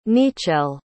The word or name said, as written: niccele